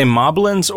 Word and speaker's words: moblin: Are they moblins or moldblins? – Oh, I pronounce it moblin to rhyme with goblin.